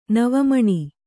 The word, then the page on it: ನವಮಣಿ: kannada